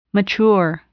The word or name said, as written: mature